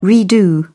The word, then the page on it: redo: British English